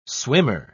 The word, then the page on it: swimmer: British English